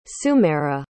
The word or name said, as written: sumehra